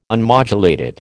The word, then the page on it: unmodulated: American English